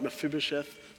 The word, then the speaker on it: Mephibosheth: Can never say his name, Mephibosheth. Is that right? Mephibosheth. It's pretty good.